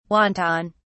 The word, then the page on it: wonton: British English